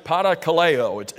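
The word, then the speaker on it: parakaleo (παρακαλέω): One of these Greek words that we have from which we get our English word comfort is the Greek word, and you pronounce it parakaleo. It's a compound word that takes the word for call. You see the K-A-L?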